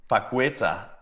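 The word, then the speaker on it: Paquetá: Og du har Lucas Paqueta, er det rigtigt udtalt? – Paqueta, og han scorer, og det annulleres altså, hvis han starter ud, og det er odds 3,25 hos Bet365.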